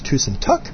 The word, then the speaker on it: Tusen takk: And he said, well, make sure, comment, reminder, he was correcting me that it's Tusen Takk, because Tusen Takk is a thousand ceilings or roofs or something. Talk, pronounced like tick-tock, is a ceiling. Tuck is thank you.